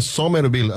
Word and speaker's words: Somerville: Saludos a Cristian Medina en la zona de Somerville. Así me dijo que se pronuncia en New Jersey.